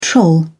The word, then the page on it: troll: British English